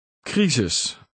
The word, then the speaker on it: crisis: Dutch C, followed by a consonant, has a K sound. – Crisis.